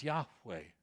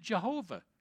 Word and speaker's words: Yahweh: So in Hebrew, they would have pronounced Jehovah Yahweh because they don't have a J or a V in their alphabet.